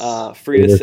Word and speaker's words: Freitas: Yep, and they have Suzuki, and they have a couple of is it Freitas? Is that how you say his name? – Yes. – Freitas, and then they have Sanchez.